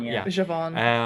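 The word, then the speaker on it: Zhavon: She's going after Javon again. Javon. God, Javon.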